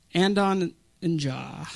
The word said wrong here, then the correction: Adonijah: I can't say the name right. Adonijah. Adonijah. My wife even warned me of that.